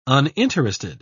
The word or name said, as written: uninterested